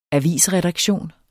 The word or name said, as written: avisredaktion